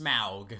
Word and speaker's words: Smaug: I think it's pronounced Smaug.